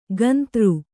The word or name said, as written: ಗಂತೃ